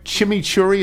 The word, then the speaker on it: chimichurri: Ray Williams writes, I love a great chimichurri. Is that how it's pronounced? – Yes.